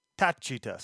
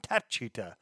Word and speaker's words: Tacita: So the Italian pronunciation. – Tacita. – Tachita. So it's a ch sound. Tachita.